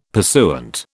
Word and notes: pursuant: British English

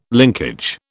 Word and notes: linkage: British English

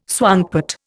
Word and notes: swankpot: Indian

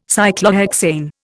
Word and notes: Cyclohexane: Indian

